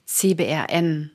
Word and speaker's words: CBRN: Dein Spezialgebiet ist ja CBRN, richtig ausgesprochen? – Genau, richtig.